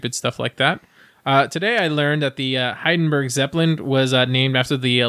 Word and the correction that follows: Hindenburg: Hindenburg, not Heidenberg.